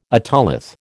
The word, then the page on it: otolith: American English